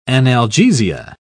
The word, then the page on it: analgesia: British English